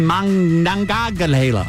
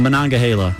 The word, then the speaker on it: Monongahela: it's the Monongahela. I said it wrong, but it's the Monongahela. I know, you'd think I'd know how to say the word Monongahela.